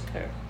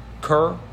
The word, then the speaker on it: Kerr: Steve Kerr. Did you? – I think it's Kerr. – Kerr? Okay. Because it's spelled K-E-R-R. Uh-huh.